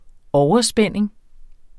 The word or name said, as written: overspænding